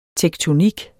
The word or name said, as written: tektonik